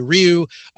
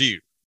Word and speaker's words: Ryu: Uh, some people say Ryu, some people say Ryu. Uh, both are wrong. Uh, the pronunciation would be more like it's it's closer to something like Lou but you you know the Japanese the way that they say those sounds is different, so it's not really lu.